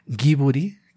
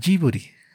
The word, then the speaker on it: Ghibli: En japonés, en lugar de que el estudio se se, llame Giburi, que sería lo más cercano a la palabra original, Miyazaki lo nombra Jiburi.